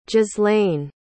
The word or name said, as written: ghislain